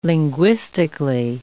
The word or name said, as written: linguistically